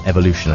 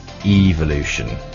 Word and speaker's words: evolution: And I believe the English pronunciation is actually evolution. That's according to Paul, my guest here in the studio. Evolution being the American way of doing things.